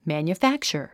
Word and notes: manufacture: mæ`njufǽktʃə r; マニュファクチャァ

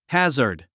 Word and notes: hazard: hǽzə r d; ハザァド

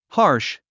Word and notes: harsh: hɑ’ː r ʃ; ハーシュ

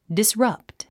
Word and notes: disrupt: disrʌ́pt; ディスラプト

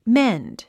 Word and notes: mend: ménd; メェンド

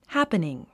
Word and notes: happening: hǽpniŋ; ハプニン

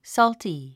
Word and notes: salty: sɔ́lti; ソルティ

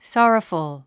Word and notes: sorrowful: sάrəfəl; ソォラフゥ

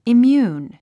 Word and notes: immune: imjúːn; イミューン

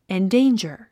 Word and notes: endanger: indéindʒə r; インディンジャァ

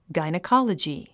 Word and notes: gynecology: ɡàinikάlədʒi; ガイニコロジィ

